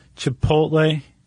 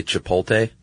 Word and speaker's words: Chipotle: Yeah, I think some people transpose the L and say Chipotle. – Yeah, they definitely do. – Chipotle.